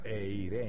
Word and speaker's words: eirene: La palabra paz en el griego es una palabrita que se pronuncia eirene.